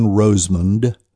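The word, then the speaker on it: Rosemond: I'm trying to pronounce the D very, very precisely. J-O-H-N-R-O-S-E-M-O-N-D. The D is often left off, and the last syllable is spelled in various ways, man, mun, mund, all kinds of ways.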